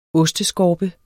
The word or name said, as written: osteskorpe